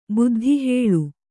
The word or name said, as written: ಬುದ್ಧಿಹೇಳು